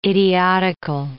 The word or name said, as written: idiotical